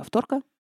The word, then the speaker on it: авторка: Поэтому на автомате ставишь ударение «авторка» по аналогии с иголкой, футболкой или мочалкой.